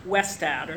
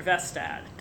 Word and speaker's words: Westad: His first name is pronounced, I think, in Norwegian, Ode, O-D-D, Arne Westad, or Vestad, W-E-S-T-A-D. Vestad has